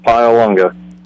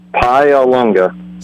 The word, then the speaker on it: Paglialunga: Pialunga. – What? – Pialunga. – Spell it. – P-A-G-L-I-A-L-U-N-G-A.